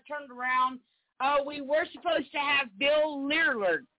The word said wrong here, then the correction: Willard: Did I pronounce his last name? Huh? – Willard, like you know the movie with the rats. Willard. – Oh, Willard.